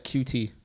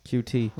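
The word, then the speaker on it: Coutee: Tim's right. It's QT. – QT. – Like he's a cutie.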